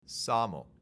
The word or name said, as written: SAML